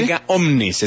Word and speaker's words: erga omnes: Erga omnes, es decir, universal.